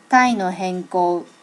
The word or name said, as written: tai no henko